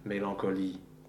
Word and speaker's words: mélancolie: But it, the word can also end with a vowel and then a schwa, and in the case you just pronounce that vowel before the schwa, mélancolie.